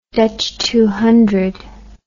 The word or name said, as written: dutch turn